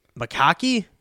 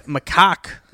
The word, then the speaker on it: macaque: M-A-C-A-Q-U-E. Makaki? – Makoke? – McCoy? – McCoy? – McCoy? – McCoy? – Macaque? Macaque. It's a crested black macaque.